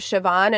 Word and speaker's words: Siobhan: I have a aunt named Siobhan and it's like S-I-O-B-H-A-N. Siobhan.